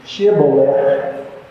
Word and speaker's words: Shibboleth: Shibboleth. S H I B B O L E T H. Shibboleth. Well, what happens, the Ephraimites could only say Shibboleth they didn't last long after they said that.